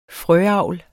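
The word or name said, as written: frøavl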